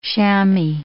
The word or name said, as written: chamois